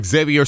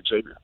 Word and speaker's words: Xavier: You pronounce it well in Spanish, Javier, and in English, Xavier.